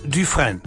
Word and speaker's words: Dufresne: D-U-F-R-E-S-N-E, etwa ausgesprochen Dufresne. Dufresne, ja, etwa so, ja, ja?